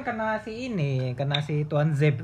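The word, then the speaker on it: Tuanzebe: Tuan Zeb itu bacanya Tuan Zeb. Tuan Zeb itu panggilan akrab.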